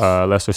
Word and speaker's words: Leicester: Leicester City in Stamford. – I know you're Bridge. – A soccer fan. So you pronounced it right.